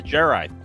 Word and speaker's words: Jerri: We're talking about Rick and Morty number 410, Star Mort, Return of the JERRI. – It's like pronounced like Jedi.